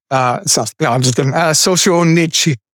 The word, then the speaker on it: Solzhenitsyn: How do you say that name? Alexander, yeah, that I know that one. But what is that? Solzhenitsyn? Okay. Alexander Socialism. Solzhenitsyn.